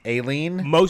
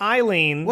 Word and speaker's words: Aileen: Her name is A-I-L. I'll spell it first and you pronounce it. A-I-L-E-E-N. – Isn't that Eileen? Aileen? – Aileen. – Aileen? – Not Eileen.